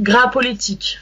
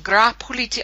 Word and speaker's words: Gras Politique: How do I pronounce the name of the group? – It's pronounced Gras Politique. – Gras Politique. Oh, that's so beautiful in your accent.